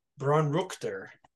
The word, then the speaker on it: Braunrichter: In 1901, Dr. Adolf Braunrichter, does that sound right, Golden Jay? – Yeah, I'm so glad that you had to pronounce that, not me.